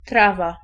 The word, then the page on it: weed: /wiːd/; [wiːd]